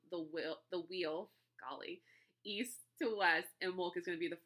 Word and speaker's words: Imbolc: But it means ewe's milk, if that helps with the pronunciation. Again, it's Imbolc. Those C's at the end always throw me off.